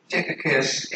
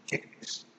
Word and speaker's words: Tychicus: I think it's Tychicus. I used to like to say Tychicus, but I think they pronounce it Tychicus.